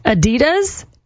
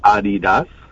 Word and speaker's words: Adidas: Adidas, not pronounced that way. – But it's actually pronounced Adidas.